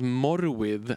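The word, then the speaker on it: Morfydd: Och hon heter Morfield Clark. – Heter ja, jag tror, att, jag tror att du uttalat Morwith. Ja, alltså, det, är det walesiska.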